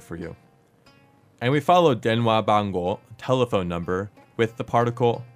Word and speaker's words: 電話番号: 電話番号電話番号電話番号 This is a little bit of a long word, so let's break down the pronunciation for you.